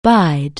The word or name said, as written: bide